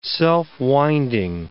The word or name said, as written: self-winding